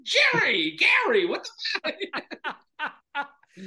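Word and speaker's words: Gerry: It's spelled Jerry, pronounced Gary.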